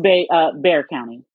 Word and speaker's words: Bexar: Yes, it's pronounced Bear County. – Whatever. – The X is silent.